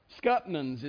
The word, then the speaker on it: scuppernong: Or in Georgia, they raise something called scuppernong. And Susie's already fussing at me because that's not how they say it in Georgia. Scuppernons is how they say it there.